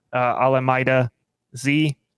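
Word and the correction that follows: Olamide Zaccheaus: Is Olamide Zacchaeus? Yeah.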